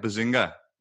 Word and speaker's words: Behzinga: Behzinga? – Yeah, you got it.